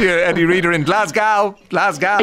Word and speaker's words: Glasgow: Glasgow. That's how you say it. Listen, this is how Joe Biden says it. Are you listening? – Glasgow.